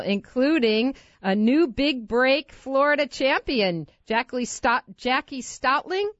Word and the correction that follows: Stolting: Is that how you pronounce her name? – Jackie Stolting.